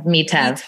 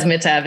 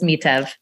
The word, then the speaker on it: Mitev: Is your last name, is that pronounced Mitev or is that pronounced differently? – Mitev. But everybody says Mitev. It's funny. I almost no longer notice it, but it's Mitev.